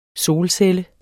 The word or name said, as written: solcelle